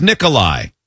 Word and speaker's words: Nikolai: My given name is Nikolai. It's pronounced the same way as Nicholas, but with a long I at the end.